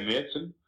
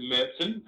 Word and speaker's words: Märzen: So we've got the Oktoberfest Märzen, which is a traditional, or I should say Märzen, as they say in Germany. – We get emails about that. – I'll say it properly then. It's Oktoberfest Märzen, and it's actually been traditionally lagered.